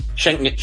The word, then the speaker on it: Schengen: ¿Cómo se pronuncia? Sí. – Schengen.